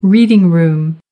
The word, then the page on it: reading room: American English